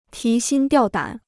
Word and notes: 提心吊胆: tí xīn diào dǎn

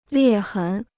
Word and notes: 裂痕: liè hén

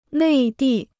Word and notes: 内地: nèi dì

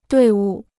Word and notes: 队伍: duì wǔ

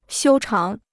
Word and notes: 修长: xiū cháng

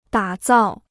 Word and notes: 打造: dǎ zào

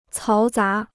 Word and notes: 嘈杂: cáo zá

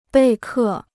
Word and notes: 备课: bèi kè

